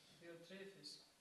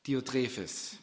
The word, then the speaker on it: Diotrephes: Wie spricht man es am besten aus? Diotrephes. Diotrephes.